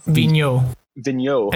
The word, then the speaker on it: Vigneault: Vigneault. Alain Vigneault. – Yeah, he got 25%. Tippett got 21%, and Sullivan got some votes as well. Corey, who you got here? – I went with Vigneault on this one, mainly good just because it was his first year in Philly, so that alone right there.